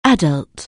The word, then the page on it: adult: anglais